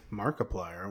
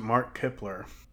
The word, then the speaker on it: Markiplier: Markiplier? What? – Yeah, it's a YouTube personality. Don't worry about it. – Oh, that's how you pronounce it. – Yeah. – I always pronounce it Mark Kipler.